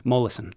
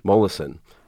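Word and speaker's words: Molaison: Mollison. – Mollison, the most famous, possibly most studied scientific subject.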